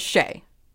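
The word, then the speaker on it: Shée: So my nickname is Shay. And Shay is spelled S-H-E-E with an accent over the first E.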